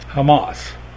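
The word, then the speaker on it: Hamas (חמס): And in modern Hebrew, do you know how that word is pronounced? Hamas.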